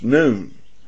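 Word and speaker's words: noon (νῦν): And thus, we need to note the Greek word here for present is N-U-N, and it's pronounced noon.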